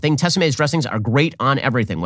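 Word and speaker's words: Tessemae's: I'm eating salad now, thanks to Tessa Mays.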